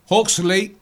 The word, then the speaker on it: Huxley: Aldous Huxley, H-U-X-L-E-Y. – Está bien, Huxley, no lo va a corregir.